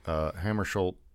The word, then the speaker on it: Hammarskjöld: Hammarskjöld, am I saying that right? – Yes.